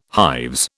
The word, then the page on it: hives: British English